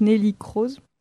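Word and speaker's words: Neelie Kroes: Alors, j'imagine que ça se prononce Nelly Kroes, j'ai un doute. – Oui, c'est ça.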